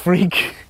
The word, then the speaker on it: Freke: So my name is Tim Freak. My name really is pronounced Freak. It's an old West Country name.